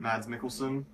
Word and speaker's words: Mads Mikkelsen: Mads Mikkelsen. – Mikkelsen? – That's where I've heard it. Mads Mikkelsen.